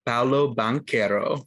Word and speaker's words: Paolo Banchero: But my favorite rookie coming into this season is Paolo Banchero.